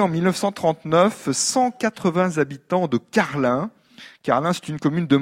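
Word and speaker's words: Carling: c'est comme ça qu'on prononce, hein, ça s'écrit ING à la fin, mais on dit bien Carlin, une rue de Carlin existe à Iteuil, ici dans la Vienne, dans cette commune résidentielle et rurale.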